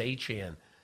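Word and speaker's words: Achane: They called him, and he said, yeah, it's Achan.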